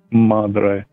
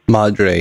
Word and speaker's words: madre: And the new job was closer to my my mudra. Mudra. Am I saying that right? Madre. – Madre, yeah.